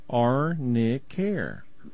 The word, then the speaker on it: Arnicare: Yeah, it's spelled A-R-N-I, and then the word care, Arnicare.